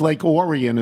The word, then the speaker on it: Lake Orion: Lake, I kept saying, we have an emailer from there, and I always say Lake Orion, but I think it is Lake Orion as well, so that's good.